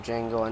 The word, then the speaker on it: Django: I like Mr. Pink from what Reservoir Dogs, and I like the Django from Django Unchained. – The D is silent, hillbilly.